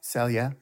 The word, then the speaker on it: Celje: Yeah, Ljubljana and Celje. Did I say that right? – Celje.